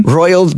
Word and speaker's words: Roald: Royal Dahl. – Am I saying that right? – Royal Dahl. – Oiled Dahl. What am I not getting? Say it again. – Roald Dahl. Roiled, like Roald Dahl.